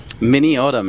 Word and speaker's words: Minneota: Minneota, Minnesota. – Minneota, we're familiar.